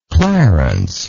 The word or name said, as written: clarence